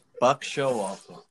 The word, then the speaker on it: Showalter: Rhymes with Tuck Gibraltar. – Didn't help me. – Buck Showalter.